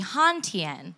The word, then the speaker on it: Hanh Tien: So, like Carolyn said, my name is Tien Tran, but my full name is actually Han Tien, which is spelled H-A-N-H-T-I-E-N.